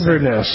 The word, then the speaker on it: Inverness: I never pronounce Inverness.